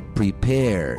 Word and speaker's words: prepare: Preparar é prepare. Termina com E, mas não falo E.